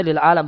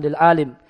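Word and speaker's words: lil alam (للعالم): Itu bacanya lil alam, bukan lil alim.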